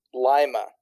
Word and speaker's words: Lima: Lima, kind of like Lima. Spelled like Lima. – But you guys just pronounce it differently. – Like the lima bean, yes.